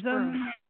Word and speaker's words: Besom: I know it means Besom. Yeah, we say Besom. We've heard several pronunciations of it. So I think it's kind of like almost a regional.